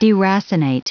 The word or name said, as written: deracinate